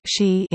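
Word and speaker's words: shi (四): 4 is pronounced shi in Japanese, which also happens to be the pronunciation for the word death, while 9 is ku, which can also mean pain or suffering.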